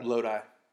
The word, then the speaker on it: Lodi: How do you say that? Lodi? Is that a Greek god? – Lodi. It's a place in California.